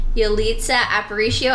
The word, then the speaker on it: Yalitza Aparicio: We've got Yalitza Aparicio. I think I said her name right. – I actually am almost positive you did. Please. Great job.